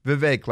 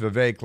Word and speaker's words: Vivek: Vivek, like cake. I always say that, too, because that's the way it was explained to me. And I say bake a cake. So it's pronounced Vivek, like bake a cake.